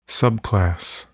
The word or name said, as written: subclass